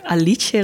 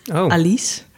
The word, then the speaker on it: Alice: De nieuwe film van een regisseur wiens naam ik pas recentelijk heb leren uitspreken, namelijk Alice Rohrwacher en niet Alice. Want het is Italiaans.